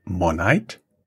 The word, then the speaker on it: Monite: Where is um, you pronounce it Monite? – Yeah.